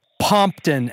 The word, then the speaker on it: Pompton: I'm not going to give you credit for this, but just for fun, it rhymes with Compton. – Rhymes with Compton, huh? – Pompton Avenue.